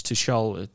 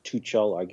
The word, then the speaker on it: Tuchel: I think Chelsea might, it's Tuchel. Tuchel, did I pronounce that correctly? – Well, it's German, so it's probably more harder. It's Tuchel, I guess. T U C H E L. So whichever, if you, that's more French the way you.